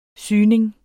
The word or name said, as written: syning